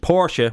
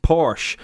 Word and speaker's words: Porsche: And now we're doing it with Porsche, which is not pronounced Porsche. And you don't have to take my word for it. Porsche even has their own pronunciation guide, which really hammers home the point, to be fair.